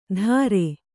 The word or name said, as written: ದರೆ